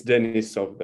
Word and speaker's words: Denisov: It's Denisov, though. – Denisov. – Yes. Yeah, but I understand you're not the first and you're not the last one to, who said that who pronounced my surname exactly the way you did.